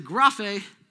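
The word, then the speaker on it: graphe (γραφή): This is pronounced graphe. Everybody say graphe.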